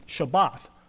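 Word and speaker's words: Shabbath (שבת): And so and the Hebrew word is a Shabbath, which the word Sabbath comes from.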